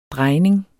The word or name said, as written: DREJNINGENS